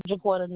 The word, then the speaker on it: Jaquetta: Jaquetta Nichols. – Spell that for me. First name. – J-A-Q-U-E-T-T-A. Last name, Nichols. N-I-C-H-O-L-S.